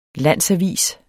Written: landsavis

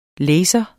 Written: LASERNES

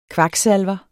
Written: kvaksalver

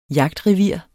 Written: jagtrevir

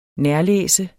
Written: nærlæse

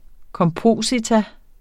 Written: kompositum

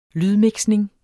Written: lydmixning

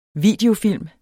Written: videofilm

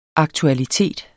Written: aktualitet

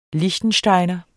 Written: liechtensteiner

